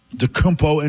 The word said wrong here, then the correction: Antetokounmpo: Giannis Antetokounmpo.